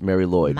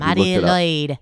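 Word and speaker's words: Mari Lwyd: M-A-R-I. – Yeah. – New word. – Yeah. – L-W-Y-D. – Yeah, but it is pronounced Mary Lloyd. – Mary Lloyd.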